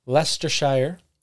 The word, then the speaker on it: Leicestershire: Leicestershire.